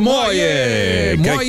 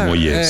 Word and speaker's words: Moyet: Io ho sempre messo l'accento sulla E, non lo so. – Non so, non ha origini francesi, mi sembra, perché è britannico. – C'entra niente, quindi non lo so. Ma sai, sì, molti dicevano Moyet, sì. – Moyet, Alison Moyet, invece Moyet. Comunque lei.